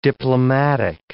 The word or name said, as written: diplomatic